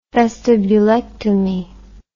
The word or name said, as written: vestibulectomy